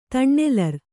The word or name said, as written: ತಣ್ಣೆಲರ್